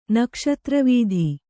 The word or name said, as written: ನಕ್ಷತ್ರವೀಧಿ